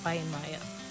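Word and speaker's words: Weihenmayer: It's spelled E-R-I-K-W-E-I-H-E-N-M-A-Y-E-R. No, the surname is like, it has a lot of letters, but it's just Weinmeier, right?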